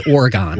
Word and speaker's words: Oregon: I just grew up saying that Oregon. Word is Oregon. – Right. – We're in Oregon, and that's the proper way to say it.